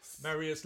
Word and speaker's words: Marius: Strictly winning professional Oti Mabuse and her partner Maurice, is that how you say it? Marius Le Pure, yeah. – I don't know why I said Maurice the first time.